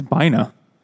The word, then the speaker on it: Bina: Short for, or maybe it's Bina. It rhymes with vagina.